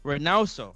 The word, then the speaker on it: Reynoso: I know Eddie Rosano, saying that wrong, Eddie Reynoso. Reynoso.